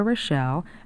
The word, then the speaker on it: Rochelle: My name is Rita Rochelle, and that's spelled R-I-T-A. Rochelle is R-O-C-H-E-L-L-E.